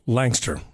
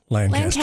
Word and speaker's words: Lancaster: It is pronounced Langster. – Langster. – It reads Lancaster. – Okay. – I always assumed it was Lancaster. – Lancaster. Okay, because it is L-A-N-C-A-S-T-E-R.